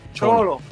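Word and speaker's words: Xolo: Es Cholo. – Cholo, porque la X suena como. – No, boca. Porque él lo pronuncia Cholo, ya lo confirmamos.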